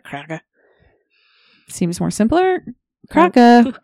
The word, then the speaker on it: krake: The name Kraken is derived from the Norwegian word Kraka? I don't know how to pronounce it. K-R-A-K-E. – Kraka. – Okay, like that.